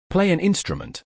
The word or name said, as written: Play an instrument